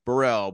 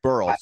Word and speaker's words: Burrell: Was it, I don't know who, but like Burrell, Braun, you know, like were some of those guys athletes as well as baseball players? Burrell, sorry, that's my bad. I mispronounced Pat's name.